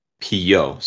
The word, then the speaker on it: Pillot: Uh, Gerard Moncomble is the writer, and Frederic Pillot, so like pillow, like P I L L O T, uh, is the artist.